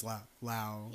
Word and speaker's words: Lao: If you didn't know, Lao. Yeah. Is that how you say it? – I think, yeah. Like, you're supposed to say Lao, not Laos.